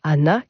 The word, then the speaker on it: Ghana: Tous ces chants traditionnels portent le nom de Hana, qui s'écrit G-H-A-N-A, ça vient de l'arabe.